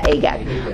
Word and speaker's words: Agag: I'll say it right, Agag. Am I saying it right? Yeah, Agag.